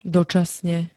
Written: dočasne

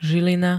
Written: zilina

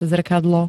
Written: zrkadlo

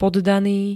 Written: poddany

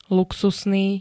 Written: luxusný

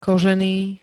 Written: kožený

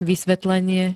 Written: vysvetlenie

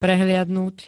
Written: prehliadnuť

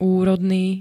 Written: úrodný

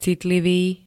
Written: citlivý

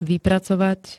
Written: vypracovať

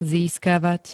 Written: získavať

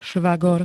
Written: švagor

